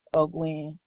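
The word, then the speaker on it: O'Guinn: Oh, Gwen.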